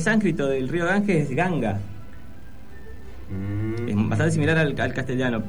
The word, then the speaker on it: Ganga: Si a un norteamericano, una persona que de habla inglesa, le decimos Ganga, la asumiría a escribirla como se escribe Gan, arma. Que se escribe G-U-N, no G-A-N.